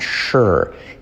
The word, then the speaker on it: sure: I'm not sure. Y esa S de sure es realmente la letra clave SH, aunque no se escriba como tal, ¿vale? No es sure, sino sure. I'm not sure.